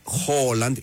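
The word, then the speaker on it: Haaland: Αντός τα παραδείγματα είναι ότι το Χάλαντ προφέρεται Χόλαντ.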